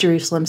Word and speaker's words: Jervsalem: I'll be speaking with a longtime friend and artist, Jerusalem, spelled J-E-R-V-S-A-L-E-M, but it's pronounced Jerusalem, so the V is the U sound.